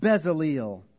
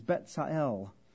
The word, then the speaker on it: Bezalel: But we would usually say, call by name Bezalel. Better pronunciation is Betzael.